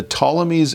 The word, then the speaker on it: Ptolemies: And the two that stand out, they're called the Seleucids in the north and the Ptolemies, that's spelled with a PT, the Ptolemies in the south.